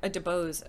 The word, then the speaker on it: DeBose: I think Ariana DeBose, I believe is the pronunciation, her last name, I think it's DeBose. I thought she was great, so good for her there.